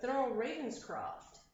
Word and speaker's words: Thurl Ravenscroft: It's a gentleman by the name of Thurl Ravenscroft who actually sings the He wins the role-playing real-life name game.